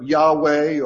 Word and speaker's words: Yahweh: Now, we would say that, pronounce that Jehovah, or some prefer Yahweh, or Yehovah, or some variation of that.